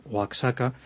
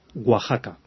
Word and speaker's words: Oaxaca: Pero un día que se me ocurrió decirle a un mexicano Oaxaca, casi me mata. Me dijo, no, por Dios, esto se dice Oaxaca.